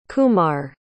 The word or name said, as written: Kumar